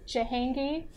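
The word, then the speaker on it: Djahangy: It's a riff on my last name, so it's Jahangi, but I'll spell that really quickly for y'all.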